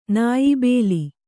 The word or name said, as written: ನಾಯ್ಬೇಲ